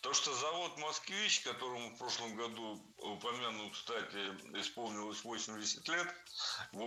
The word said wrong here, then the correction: упомянуть: Было сказано «упомянуть», «упомяну», но это неверно. Следует говорить «упомянуть», «упомяну». Ударение в этом глаголе ставится на последний слог.